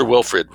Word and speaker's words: Wilfrid: W-I-L-F-R-I-D, I'm not mispronouncing Wilfried.